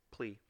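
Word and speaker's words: P'Li: I've always just pronounced it Ty Lee based off the way it was spelled. – Yeah, it's just plea. – P. Apostrophe L-I. – Yeah. Plea.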